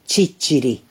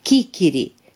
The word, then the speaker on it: ciciri: Localnicii palermitani i-au ucis pe străzi pe toți acei francezi care nu puteau pronunța cuvântul sicilian ciciri, adică năut, spunând în schimb chichiri sau sisiri.